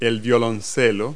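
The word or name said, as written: violoncello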